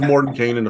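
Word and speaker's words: Mordenkainen: So it is Mordekainen officially in the pronunciation guides you find in 5th edition.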